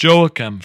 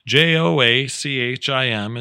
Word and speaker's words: Joachim: And it's pronounced Joachim, friends. Sometimes it doesn't just roll off your tongue, but nonetheless, J O A C H I M is pronounced Joachim.